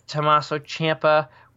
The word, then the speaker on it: Tommaso Ciampa: And your main event, the NXT champion, Tommaso Ciampa, did I say it right? – Yep.